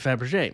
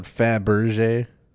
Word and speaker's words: Fabergé: No, only... it's spelled Fabergé. – Yeah, Fabergé. – Why does it sound like everybody said Fabergé? – Because that's what your brain assumes it is because of our language.